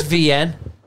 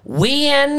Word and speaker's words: Wian: His name is VN, right? – VN. – So he's South African and it's spelled W-I-A-N, right? So like car play will be like... It'll be like, Wien is calling you.